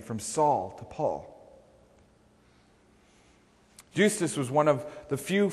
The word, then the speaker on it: Justus: Now, there are those who say Justice is not properly pronouncing this word. It's Justus. But Justice, Justus.